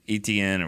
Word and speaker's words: Etienne: I mean, because the way Travis Etienne or whatever his name is, ran the ball. You said it right. Good job. – Is that right? – Yeah, Etienne, right? – It doesn't look right.